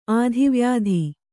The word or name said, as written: ಆಧಿವ್ಯಾಧಿ